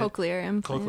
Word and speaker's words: cochlear implants: Cochlear implants? Is that how you say it? – Cochlear implants.